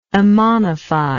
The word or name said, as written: ammonifier